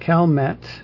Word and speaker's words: Calmet: And according to Who Be Gone, Calme actually would be the pronunciation of the French name.